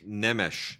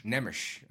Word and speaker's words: Nemes: Laszlo Nemes, I think? – Nemesh. – Nemesh. – Yep, that's right. Laszlo Nemesh.